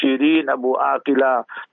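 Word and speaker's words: Shireen Abu Akleh: So we say sister in humanity, Shireen Abu Aqila. That's the proper way of pronouncing it.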